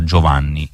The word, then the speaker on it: Giovane: Allora, buonasera cara, io volevo dirle che il nome di Giovane si pronuncia Giovanni in brasiliano, senza nessun accento, come se fosse Giovanni in italiano.